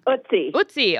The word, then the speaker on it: Ötzi: But like everyone, I mean, I've been so fascinated with the Iceman Ötzi. How do you pronounce it? I'm probably saying it wrong. – Ötzi.